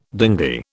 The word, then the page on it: dinghy: British English